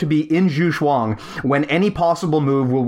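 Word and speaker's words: Zugzwang: So Zhuxuang is a German word spelled...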